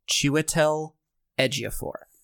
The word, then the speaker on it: Chiwetel Ejiofor: Oh my god, learn how to say his name. – How do you say it? – Chiwetel Ejiofor. – Ejiofor. Is that your issue with my saying his name? – Well, you say, you're saying like Chiwetel.